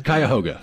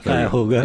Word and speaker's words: Cuyahoga: Yes, Cuyahoga. – Cuyahoga.